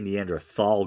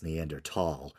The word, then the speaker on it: Neanderthal: Feel free to correct me and let me know that Neander, I used to say Neanderthal growing up, but apparently it's Neanderthal, uh, or at least that's the more popular pronunciation now.